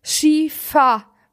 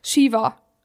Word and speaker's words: Shiva: Shiva mit V. Also, Shiva. – Ah, okay. S-H-I-V-A. – Ja, aber meine Mutter sagt immer Shiba, weil die kann es nicht aussprechen.